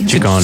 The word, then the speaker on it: Ciccone: Veronica Ciccione. – Ciccone. – Ciccione. What is it, sir? – Well, in Italian, it works the other way. So the double C's are hard C's and the singular C's are soft C's. – So Ciccione. – Ciccone. – Ciccone. Madonna Ciccone. God, why'd she drop that? That's good. It's Ciccone.